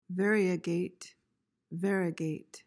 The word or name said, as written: variegate